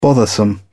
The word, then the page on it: bothersome: British English